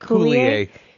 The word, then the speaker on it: Coulier: Coulier.